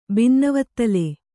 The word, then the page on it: ಬಿನ್ನವತ್ತಲೆ: binnavattale